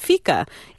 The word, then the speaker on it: fika: You had an interesting part of the cookbook where you talked about, I guess it's pronounced fika in Sweden, yep.